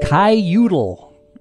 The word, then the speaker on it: Kiyoodle: All right, so let's move on to round 8, and our word for round 8 is Kaiyoodle. Not Caillou, Kaiyoodle. And that is spelled K-I-Y-O-O-D-L-E.